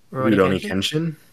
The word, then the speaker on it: Rurouni Kenshin: Rurouni Kenshin. – Yeah. Thank you. – Okay. – Nice. We got the duet going. – Controversial. You know what's going on with Rurouni Kenshin.